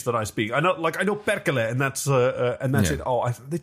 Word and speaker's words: perkele: Perkele. – Perkele. – You might have to roll your R's a little bit, but I won't.